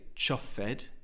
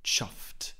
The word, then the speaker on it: chuffed: It's a one syllable word. So we don't say chuffed, that's two syllables. It's simply chuffed. And also the ED ending has a soft T sound, because before the ED we have the letter F.